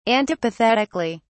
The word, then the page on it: antipathetically: British English